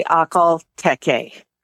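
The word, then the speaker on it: Akhal-Teke: Most people in the United States say Aquatechie. But I've heard it pronounced many different ways. Aquatechie. Over in Russia, they add a lot of spit to it.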